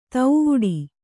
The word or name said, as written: ತೌವುಡಿ